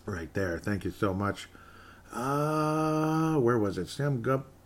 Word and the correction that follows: Gupta: I keep saying his name wrong. Sam Gupta.